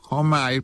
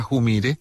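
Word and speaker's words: Humire: Se trata de Joseph Homaire, pronuncian algunos, pero él dice que se pronuncia Jumire.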